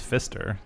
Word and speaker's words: Pfister: I mean, his name's Pfister. It's a pretty awesome name. – Silent P.